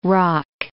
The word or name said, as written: rock